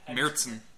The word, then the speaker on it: Märzen: Marzahn? – Märzen.